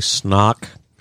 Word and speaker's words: Snock: My real last name is actually Snock. S-N-O-C-K. – Yes. – So it's Justin Snock? – Yes. It's spelled Snock. C-I-D-E-R.